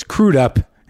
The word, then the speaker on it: Crudup: Billy Crudup is on the show today, and this is the first time I've ever pronounced it Crudup instead of Crudup, which is wrong. So it's Crudup, and he's here.